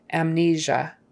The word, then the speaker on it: amnesia: That S in there, it's not an S, not amnesia or amnesia. It's a Z, that kind of a ZH kind of sound, like there is in treasure. Amnesia. Stress on the second syllable.